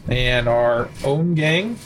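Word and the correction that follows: Ommegang: Did I say that right? – Ohma Gang.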